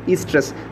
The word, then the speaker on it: estrus: The O is silent, O-E-S-T-R-U-S, estrus cycle.